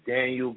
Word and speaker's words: Danyeil: My name is Daniel Green. It's spelled D-A-N-Y-E-I-L.